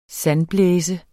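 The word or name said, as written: SANDBLÆSE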